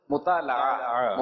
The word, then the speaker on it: مطالعہ: اچھا اصل تلفظ ہے مطالعہ مفاعلہ مناظرہ مطالعہ